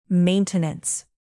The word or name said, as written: maintenance